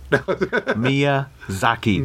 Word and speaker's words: Miyazaki: Miyazaki. – Miyazaki.